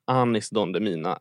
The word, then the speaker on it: Anis Don Demina: Nu sa du nog Demina igen. Men om vi har det så, jag har faktiskt klippt ut lite så att ifall man missade gårdagen så ska man få lyssna lite på hur det lät. Så här, om man inte känner till honom, Anis Don Demina är hur det uttalas.